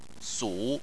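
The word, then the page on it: su: tone 3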